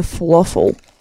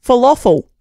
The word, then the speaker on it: falafel: Falafel. – Falafel. – Falafel. – No, you're putting O's in it. There are no O's in falafel. Falafel. Damn it! – Falafel. – Falafel. – Falafel.